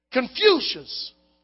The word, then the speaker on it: Confucius: Confucius.